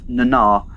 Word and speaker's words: Nana: A quick small prime corrective: the actress who plays Major Kira, her name is pronounced Nana.